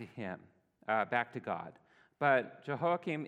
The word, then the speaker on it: Jehoiakim: And we have King, and I'm going to butcher his name, Jehoiakim. Okay, I'm seeing a nod, so I said it right this time.